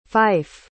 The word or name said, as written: fyfe